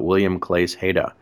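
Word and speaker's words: Willem Claesz. Heda: I'm thinking of one example, Oysters After W.C.H., which refers to the Flemish painter William, I'm going to butcher his name, but William Claes Heda. – Yeah, that's perfect.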